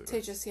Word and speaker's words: Tejas: It was how do you pronounce it? Tejas? Is that Texas? – Tejas, yeah. Tejas. – I just assume that it's like a Spanish kind of JH kind of sound.